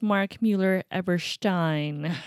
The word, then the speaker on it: Mark Mueller-Eberstein: And for the record, if Lawrence is listening, it is Mark Mueller-Eberstein.